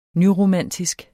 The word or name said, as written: nyromantisk